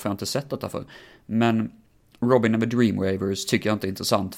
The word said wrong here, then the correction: Dream Weavers: Eh, jag kommer säga titeln igen, för jag kanske uttalar lite fel. Robin and the Dream Weavers. Så det är väl drömvävare som är sista ordet då på engelska. Dream Weavers.